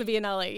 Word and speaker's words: Biennale: And a lot of times people are intimidated to tackle something like the Biennale. Is that how you say it? – Yes, the Biennale.